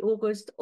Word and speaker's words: August: But I always say my name is Steffi August. August like September. Easy to remember.